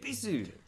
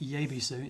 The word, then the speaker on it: Yebisu: Ebisu. – Yeah, Ebisu, yes. It's not Yebisu. It's spelt Yebisu, but you don't pronounce the Y.